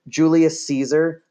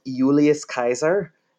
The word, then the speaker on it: Julius Caesar: So like, for example, Julius Caesar is actually spelt Julius Kaiser. That's actually how you say it in Latin. I-U-L-I-U-S is Julius.